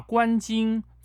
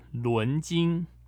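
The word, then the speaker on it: 纶巾: 或许是不小心吧，他把“ 关金” 错读为“ 伦金”，被广大网友批评，因此。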